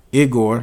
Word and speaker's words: Igor: Igor, am I saying that right? – Mm-hmm.